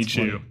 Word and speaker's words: Chu: They did like a spinoff with his sister, and it's called Chu, but it's spelled like their last name, C-H-U.